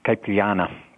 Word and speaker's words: caipirinha: And it was there that the concierge introduced me to this caipirinha.